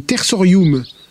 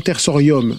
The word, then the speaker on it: tersorium: Chez les Romains, on invente un nouvel outil, le tersorium, et non tersorium, en latin, le U se prononce ou, pas O.